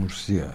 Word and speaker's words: Murcia: Murcia diye okunuyor değil mi? – Murcia.